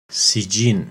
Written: Sijjin

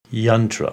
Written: Yantra

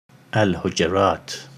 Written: Al-Hujurat

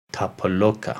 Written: Tapoloka

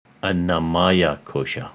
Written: Anamaya Kosha

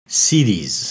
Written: Siddhis

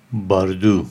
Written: Bardo

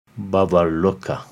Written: Bhuvarloka

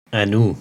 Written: Anu

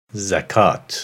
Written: Zakat

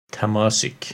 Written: Tamasic

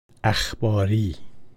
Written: Akhbaries

